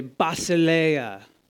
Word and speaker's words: basileia: Can everybody say basileia? – Basileia. – Accent on the ea. Basileia.